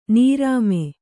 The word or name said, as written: ನರಮು